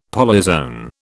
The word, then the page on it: polyzoan: British English